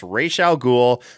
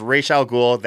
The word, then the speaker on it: Ra's al Ghul: It's Ra's al Ghul. Sorry, Christopher Nolan, it's Ra's al Ghul. That's how it was. That's how it's supposed to be.